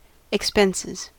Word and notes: expenses: American English

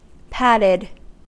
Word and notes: patted: American English

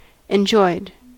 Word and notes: enjoyed: American English

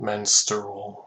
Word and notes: menstrual: American English

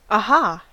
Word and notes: aha: American English

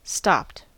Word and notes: stopped: American English

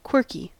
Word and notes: quirky: American English

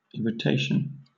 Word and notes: irritation: British English

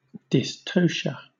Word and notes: dystocia: British English

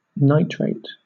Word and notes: nitrate: British English

